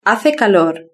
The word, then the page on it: hace calor: European Spanish